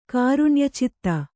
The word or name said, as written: ಕಾರುಣ್ಯಚಿತ್ತ